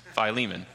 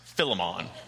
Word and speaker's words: Philemon: And just in case you are wondering, because I did have a comment about this last week, yes, it is pronounced Philemon. It is not pronounced Philemon. And if you don't believe me, well, that's on you. That is the proper pronunciation. I almost promise because Koine Greek is a dead language, so all I can tell you is that the I is elongated. It is not a shortened I.